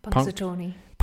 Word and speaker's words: Punxsutawney: Punxsutawney.